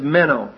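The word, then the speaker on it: meno (μένω): Continuing here, is the Greek word is meno, M-E-N, long O.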